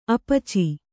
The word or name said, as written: ಅಪಾಚಿ